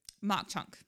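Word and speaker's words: Mauch Chunk: So we decided to go visit Mau Chunk. Is that how you say it? – Mock Chunk.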